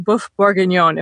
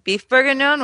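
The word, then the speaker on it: bœuf bourguignon: I feel like I hear a lot of people pronounce it beef bourguignon, which is sort of a mashup of the English and French pronunciation, but it is heavily featured in Julie and Julia.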